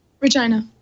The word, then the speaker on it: Regina: Regina or Regina? How do you say that? – Regina. – That's what I thought. Regina, Saskatchewan. I know how to say that.